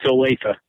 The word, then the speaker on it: Olathe: Is it Olathe? Am I saying it wrong? – It's Olathe.